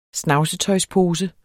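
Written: snavsetøjspose

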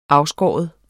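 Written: afskåret

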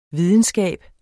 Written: videnskab

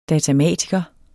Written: datamatiker

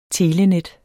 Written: telenet

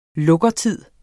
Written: lukkertid

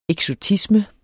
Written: eksotisme

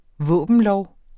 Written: våbenlov

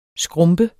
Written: skrumpe

